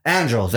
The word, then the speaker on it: Andros: Anthros, I'm saying his name wrong. Andros, that's Andros. How you say his name.